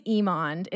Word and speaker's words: Emond: My last name is Emond.